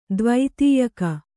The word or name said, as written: ದ್ವೈತೀಯಕ